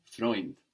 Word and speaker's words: Freund: A friend in German is ein Freund. Ein Freund. – Ein Freund. – This is spelled F-R-E-U-N-D. But of course, the D on the end there is hardened, sounding like a T.